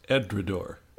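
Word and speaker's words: Edradour: I believe it's pronounced Edredor.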